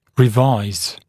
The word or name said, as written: revise